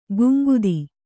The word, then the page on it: ಗುಂಗುದಿ: guŋgudi